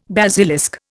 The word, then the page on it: basilisk: Indian